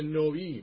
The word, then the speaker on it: نوی: شما هم بنابراین از اعتراضی که کردین دست بردارین، آشتی کنید و به نوی یعنی به نوی به اصطلاح نوی باید تلفظ بشه اینجا، به نوی یعنی به نوینی، به تازگی دوباره باز پیمان شوید.